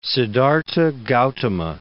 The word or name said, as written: Siddhartha Gautama